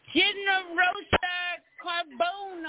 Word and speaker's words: Ginearosa Carbone: We got Ginearosa Carbone on tonight. Did I do good? – Yeah, that's pretty good.